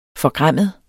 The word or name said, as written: forgræmmet